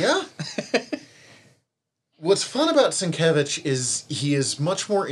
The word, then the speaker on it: Sienkiewicz: Okay, so tell me how to say the name again. Bill Sienkiewicz? – Yes. – Sienkiewicz.